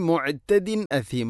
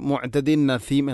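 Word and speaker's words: മുഅ്തദിൻ അസീം (مُعْتَدٍ أَثِيمٍ): തൻവീനു ശേഷം ഹംസ വന്നാൽ ഇദ്ഹാറാണ് ചെയ്യേണ്ടത്. അവൻ മണിക്കാൻ പാടില്ല. പക്ഷേ നമ്മളൊക്കെ ഓതിപ്പോകുമ്പോൾ മുഅ്തദിൻ നസീം എന്നായിരിക്കും. അധികം ആളുകളും ഓത് ശ്രദ്ധിച്ചു നോക്കുന്നു. മുഅ്തദിൻ നസീം എന്നായിരിക്കും വായിക്കുക. അത് അറിയുന്ന ആളുകളിൽ പോലും അങ്ങനെ ഉണ്ടാവുന്നു എന്ന കാര്യത്തിൽ ഗൗരവത്തോടെ ശ്രദ്ധിക്കേണ്ട വിഷയമാണത്.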